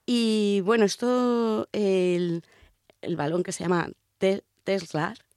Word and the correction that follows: Telstar: creo que no lo estoy pronunciando nada bien el nombre, pero bueno, es que no soy muy futbolera. – Teslar. – Telstar. – Ah.